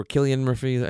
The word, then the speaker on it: Cillian Murphy: I think Cillian Murphy, or Killian Murphy, I think is how you say his name.